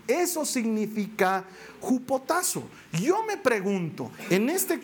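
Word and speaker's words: jupotazo (ὑποτάσσω): Te quiero explicar, este sométanse viene de una palabra griega que se pronuncia jupotazo. A ver, di Jupotazo. Así se pronuncia, no estoy inventando.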